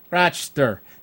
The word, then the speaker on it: Rochester: There's only Rochester. It's only 3 syllables in Rochester.